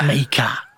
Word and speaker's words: Mika: Mika. It's the only way you can say his name.